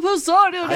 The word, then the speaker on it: Poseidon: He directed last week's Beyond the Poseidon Adventure, the movie that broke me. – You're not saying it right. Beyond the Poseidon Adventure! – I don't think anyone in the movie said it that way.